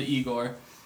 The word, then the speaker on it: Igor: So if Flower Boy, the previous album to Igor, that's how it's pronounced, if Flower Boy was like his...